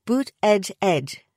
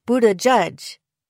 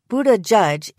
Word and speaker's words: Buttigieg: On his Twitter, Buttigieg himself suggests that people should pronounce it like Bood-eh-jedge, though his husband offers the alternative Buddajudge.